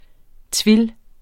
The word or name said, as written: tuile